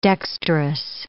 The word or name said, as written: dextrous